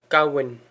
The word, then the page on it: gamhain: gow-in